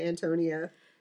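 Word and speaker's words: Ántonia: I thought it was My Antonia. – You know what? I think some cultures pronounce it that way, and this is the Bohemian way. – Okay. – The accent's on the first A.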